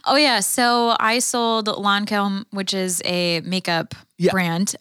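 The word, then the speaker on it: Lancôme: Lancome.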